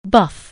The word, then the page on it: buff: anglais